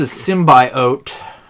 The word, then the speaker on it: symbiote: If he's a symbiote. – A symbiote? – Symbiote. – That's how they pronounced it in the movie trailer.